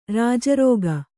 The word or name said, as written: ಋಜುರೇಖೆ